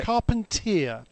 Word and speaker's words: Carpentier: He was a long-time Skip Barber instructor, and I saw him this morning, and he corrected us on the pronunciation of his name. Okay. Carpentier. Carpentier was coined by Gerardo Bonilla, who's one of his long-time schoolmates, if you like, or teacher mates at the Skip Barber Racing School.